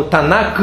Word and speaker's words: Танах: А вообще Ветхий Завет на иврите Танах называется. Э, ну, Танак вообще-то, но К на конце в иврите как Х читается.